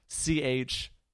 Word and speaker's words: Toronto: Well, you already have a bit of it going there because you pronounced it Toronto with the soft T. – Yeah, like Toronto. I've heard it. I've heard people call it Toronto. – Like we say like basically Churanna, like Toronto. – Toronto. – A lot of people say it like that,